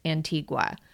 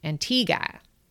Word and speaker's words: Antigua: Now, the English teacher in me wants to pronounce it Antigua.